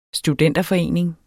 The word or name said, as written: STUDENTERFORENING